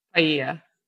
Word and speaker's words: paella: Paella. – Bam. Got that. Even had the pronunciation right.